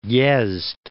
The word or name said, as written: Yezd